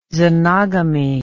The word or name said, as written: xenogamy